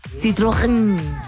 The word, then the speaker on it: Citroën: Hay que pronunciar bien porque es francés. Citrogen. Es que tiene la E, tiene unas cremillas. Citroën fue fabricado el 2010 y el 2018 tu Citroën, que no es lo mismo que una citroneta.